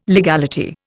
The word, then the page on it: legality: Indian